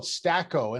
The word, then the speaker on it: Staqo: So big announcement out from the company talking about a partnership with a company called Stako, and it's spelled S-T-A-Q-O.